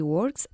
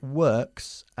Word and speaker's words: work: She works as an actress. – She works as an actress. Cuidado también con la pronunciación del verbo to work. No work, sino work.